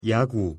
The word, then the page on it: 야구: Korean